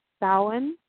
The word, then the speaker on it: Samhain: It's spelled Samhain, but I believe it's pronounced Samhain.